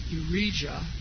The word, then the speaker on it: Uriah: We read in verse 13 that when he reached the Benjamin Gate, the captain of the guard, whose name was Uriah, that wouldn't be pronounced Jah, but Yah, son of Shelemiah, the son of Hananiah, arrested him for treason and said, you are deserting to the Babylonians.